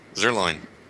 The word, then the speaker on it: Zuerlein: I guess I'm saying his name right. Be Zuerlein. – That's what it is. Zuerlein.